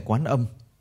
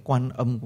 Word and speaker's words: Quán Âm: Quán là nhìn, là ngó, như nói ngày quán âm. Quán còn đọc là quan, thành ra đôi khi người ta nói quán âm cũng được, nói quan âm cũng được.